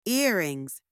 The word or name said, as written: earring(s)